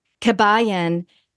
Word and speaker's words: Kabayan: For those of you that don't know Joyda, who, by the way, is a musician and singer-songwriter and mother, she hails from Kabayan, Benguet. Did I say it right? – Yes.